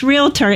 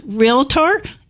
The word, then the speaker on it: realtor: Please know, it's realtor. It's just two syllables. I know that in the South, we love making three syllables out of a two-syllable word. We even can make two syllables out of a one-syllable word.